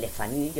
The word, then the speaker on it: Le Fanu: También tenemos a Carmilla, de Sheridan Le Fanu. Yo lo pronuncio a lo francés, pero bueno, era un apellido francés, pero era un inglés, Sheridan Le Fanu.